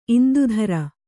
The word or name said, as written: ಇಂದುಧರ